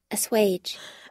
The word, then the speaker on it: assuage: Assuage. – Assuage.